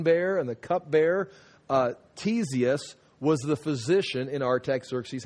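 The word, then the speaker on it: Ctesias: This is pronounced Tesius, just like T-E-S, the C is silent.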